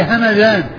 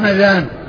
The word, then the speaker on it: همذان: أما ما كان نسبة إلى بلدة فهي همدان, مدينة من المدن اسمها همدان بالميم المفتوحة والذال المعجمة.